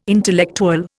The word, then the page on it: intellectual: Indian